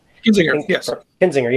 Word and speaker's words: Kinzinger: but Adam Kinzinger. – Kinzinger, yeah, from Illinois.